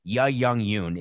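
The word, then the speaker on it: Yuh-jung Youn: at least make sure you say her name right." Ya Jung Yoon is her name.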